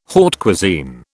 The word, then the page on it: haute cuisine: British English